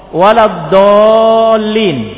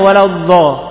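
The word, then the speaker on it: waladdallin (وَلَا الضَّالِّينَ): Sebagian orang tidak bisa baca dhad, mesti lari ke dha. Bacanya waladdha, waladdha, dha, dha. Lari ke situ bacanya, ke dha. Padahal bukan dha, bukan dha, tapi apa? Dod waladdallin, dia bacanya waladdallin.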